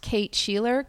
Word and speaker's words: Shelor: So I'm at Kate Shelor, KateSheLor. – Which means I've been mispronouncing your name until this moment. Shelor. Sorry.